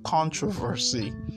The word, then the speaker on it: controversy: In this word as well, the stress is always on the first syllable, so you just say controversy.